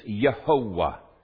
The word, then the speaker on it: Yehovah: Okay, it means praise Jehovah, or if you want probably a more accurate pronunciation, praise Yehovah. Praise Yehovah.